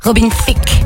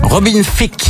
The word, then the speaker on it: Robin Thicke: Robin Thicke. – Robin Thicke. – Robin Thicke.